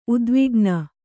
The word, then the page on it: ಉದ್ವಿಗ್ನ: udvigna